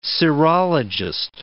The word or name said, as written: serologist